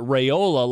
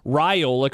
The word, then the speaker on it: Raiola: Real quick, Don wants to know, why did John go back to pronouncing it Raiola like it rhymes with Crayola, especially after we finally started saying Raiola correctly?